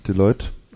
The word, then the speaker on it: Deloitte: Deloitte, ne? – Deloitte.